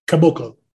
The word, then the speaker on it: Caboclo: The Celtics, since we last talked, signed Bruno Capogolo. I hope I said that right. Probably not. – No, that's what you call Capogolo. You're doing a Sopranos impersonation. It's Caboclo.